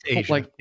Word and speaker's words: Aja: So it's spelled AJA. So if you never knew how to pronounce it, like Aja, or it's Asia, it's Asia. – Like the continent.